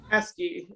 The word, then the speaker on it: Eskey: Eskey. It's like a key in a lock.